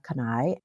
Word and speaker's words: Kanai: My name is Aya Kanai.